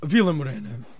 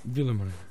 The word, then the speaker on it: Vila Morena: Vila Morena. Vila Morena.